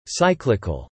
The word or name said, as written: cyclical